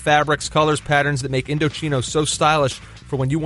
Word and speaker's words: Indochino: There's a note at the top that tells you how to pronounce Indochino. Who doesn't know? Indochina. Who would have think that? – Well, no, I had to tell you off here it was Indochino.